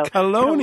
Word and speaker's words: Chelone: It's spelled C-H-E-L-O-N-E. And somebody told me to say it like baloney, but with a K in the beginning. So Chelone. – Chelone.